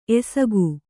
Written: ಎಸಕ

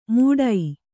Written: ಮೂಡೈ

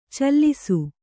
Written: ಚೆಲ್ಲಿಸು

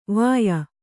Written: ವೆಯ

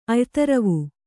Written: ಅಯ್ತರವು

